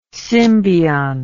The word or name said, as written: symbiont